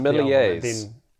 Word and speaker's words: sommelier: I'm happy to say sommelier. I think that's right. – No, that's very good.